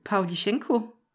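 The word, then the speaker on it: Pavlichenko (Павличенко): Pavlichenko. – Ja. – Det lyder meget rigtigt, det gør.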